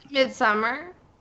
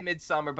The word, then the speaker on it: Midsommar: S-O-M-M-A-R. How much can you pronounce it? Somar. – Midsummer? – No. Yeah, a lot of people say Midsommar, but even I, who's not a huge fan of the film, won't disrespect it by saying Midsommar.